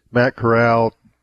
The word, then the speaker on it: Corral: Matt Corral, I think that's how you say his name. Correct me, John. I'm more of a print guy than I am a radio guy. But did I get that right? – That's right.